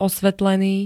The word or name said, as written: osvetlený